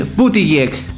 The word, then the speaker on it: Buttigieg: Porque dice que va a nominar al el ex precandidato presidencial, y aquí ustedes me corregirán si lo digo mal, Pete Buttigieg, no sé si lo pronuncie bien. – El que hacía de chileno. – Pete Buttigieg, como secretario de transporte.